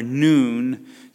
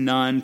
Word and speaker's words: Nun: And they came near before Eleazar, the priest, before Joshua, the son of Nun, or Noon, to be more correct with the pronunciation. It isn't Nun.